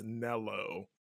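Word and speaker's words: Nelo Angelo: Like, I had to train myself, like, no, it's Nello. And not because I knew the history, just Nero just sounded more natural to me.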